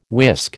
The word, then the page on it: whisk: American English